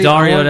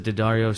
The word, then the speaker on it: Daddario: I want to see Daddario. That's how you pronounce the last name because of Daddario strings. I believe, like guitar strings.